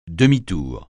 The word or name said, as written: demi-tour